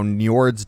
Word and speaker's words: Njord: It's now Njord's Dock, or Njord's Dock, if you want to use, because I think they call him Njord in Norwegian. The actual pronunciation.